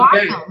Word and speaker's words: Wacom: It is. If you go to their site, they call it Wacom.